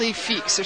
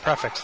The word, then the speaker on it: prix fixe: You can pay even more to get they call this a price fixed dinner, but they spell it P-R-I-X-F-I-X-E. Is that how it's pronounced? – It's probably pronounced in a French kind of way. Like, Plie fixe or something like that. – It's not prefix though, right? – No.